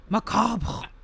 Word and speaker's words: macabre: You're going for like, your definition of macabre is like, I can't even pronounce that. I was like letting you say it. I was trying to avoid it. Macabre. – That's it. – You nailed it.